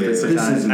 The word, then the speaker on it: Isekai: Isekai, yeah.